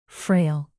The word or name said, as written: frail